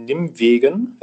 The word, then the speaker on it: Nimwegen: Du bist 1971 in Nimwegen, wenn ich es richtig ausspreche, geboren worden. – Ja, also auf Deutsch ist das richtig ausgesprochen, ja.